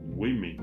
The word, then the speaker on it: women: Otra palabra irregular es uh, woman, W-O-M-A-N, una mujer. En plural, la palabra se pronuncia women. W O M E N.